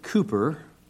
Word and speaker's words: Cowper: William Cooper, spelled C-O-W-P-E-R, we want to pronounce it Cowper, but the English pronounce it Cooper, was born in 1731 in England.